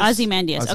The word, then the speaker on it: Ozymandias: How do you say it in English? – It's called Ozymandias. – Ozymandias.